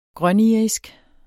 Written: grønirisk

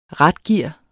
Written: ratgear

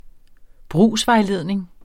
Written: brugsvejledning

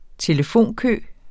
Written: telefonkø

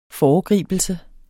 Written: foregribelse

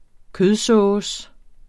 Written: kødsovs

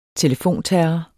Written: telefonterror

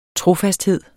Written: trofasthed

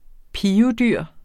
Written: PIVEDYR

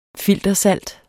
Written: filtersalt

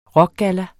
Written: rockgalla

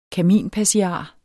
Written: kaminpassiar

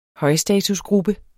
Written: højstatusgruppe

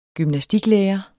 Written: gymnastiklærer

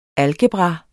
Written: algebra

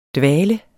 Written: dvale

